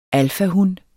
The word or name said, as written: alfahun